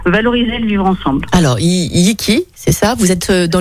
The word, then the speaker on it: YiQi: Yiki, Yiki ça veut dire ensemble en chinois.